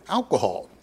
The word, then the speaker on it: alkuhl: Well, it's derived from the Arabic word alcohol, meaning essence. It's spelled A-L-K-U-H-L, but pronounced the same way.